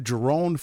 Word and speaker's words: Jerone: Go to Jerone for Congress. My name is spelled J-E-R-O-N-E. My father's half Native. – So J-E-R-O-N as in Nancy.